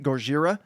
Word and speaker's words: Gojira: As an American, as a white American man, you can't mispronounce Gojira. even if you want to pronounce it like a white guy, you say it like I'm saying it, right? Gojira. You don't say Godzilla. Like, the two don't sound the same.